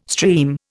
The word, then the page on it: stream: Indian